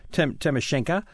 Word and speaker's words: Tymoshenko: Tymoshenko. Yeah, usually, even though it's an O in the end, Ukrainians, unlike Russians, usually don't pronounce it as an O. It's more of a Yevtushenko.